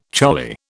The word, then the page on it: charlie: British English